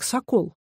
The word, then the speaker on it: сокол: Так почему же гол как сокол? Да потому что сокол вообще здесь ни при чем. Сокол, с ударением на последнем слоге, это старинное стенобитное военное орудие.